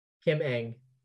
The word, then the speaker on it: Ng: Kim Ng. – Ng? – Yes, it's pronounced like ING.